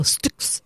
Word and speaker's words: Styx: Und ich würde jetzt mal sagen, wir einigen uns vielleicht auf die Aussprache Stücks, also mit St weil ansonsten werden wieder 50 Prozent unserer Hörwesen komplett rumarmocken, weil der eine es so macht, der andere so.